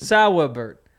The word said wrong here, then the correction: Saubert: No, Saubert. – It's just two syllables. Sau.